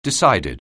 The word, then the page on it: decided: anglais